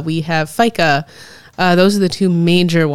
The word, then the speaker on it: fika: ask that same question is, in Britain, when they have tea time in the afternoon, in Sweden they have fika, which is the break you take with family or friends over coffee and some baked goods, usually.